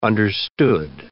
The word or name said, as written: understood